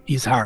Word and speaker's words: Yizhar: You mentioned it's embodied in people like, I don't know how to pronounce it, Yizhar Shai. – Yizhar, yeah.